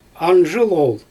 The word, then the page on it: anzhyl ool: Xyzyl